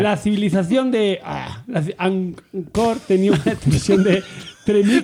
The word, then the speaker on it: Angkor: A-N-G-K-O-R. – Vale, pero no el esfuerzo. Ancor.